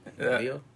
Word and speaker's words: Bolillo: It's uh, Bolio con patas de pollo, right? – Bolillo.